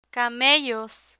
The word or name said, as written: camellos